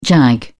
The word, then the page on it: jag: British English